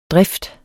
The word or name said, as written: drift